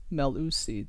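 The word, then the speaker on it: Mellusi: Top rusher, Chez Melusi, and I had to look up how to pronounce that because it's not obvious from the spelling, but it's Melusi, Chez Melusi, 106 yards a game.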